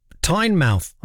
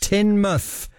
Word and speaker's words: Tynemouth: She says, "Hi, Rich, writing to you from Tynemouth." I'm saying it wrong deliberately. In Devon, as I shout at the screen, it's pronounced Tynemouth.